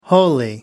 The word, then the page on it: wholly: American English